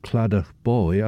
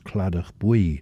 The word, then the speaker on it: Claddach Bwy: although it's spelt Claddach Boy, I'd say that it represents Claddach Bwy, so perhaps should be pronounced Claddach Bwy, meaning the yellow riverland.